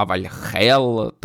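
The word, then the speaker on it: Вальгалла: я не знаю, як передати, в тому, що вони навіть те, як вони вимовляють не Вальгала, скажімо так, а Вальхел.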